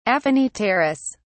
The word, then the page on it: aphanipterous: British English